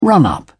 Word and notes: run-up: British English

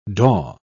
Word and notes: daw: British English

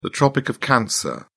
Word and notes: tropic of cancer: British English

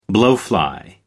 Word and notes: blowfly: American English

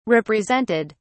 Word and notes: represented: British English

